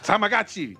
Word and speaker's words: Tamagotchi: It's T-A-M-A-G-O-T-C-H-I. – Yes. – Tamagotchi. – That's correct. – That is correct. – It's like the one Japanese friend playing tag with his white friends. Tamagotchi.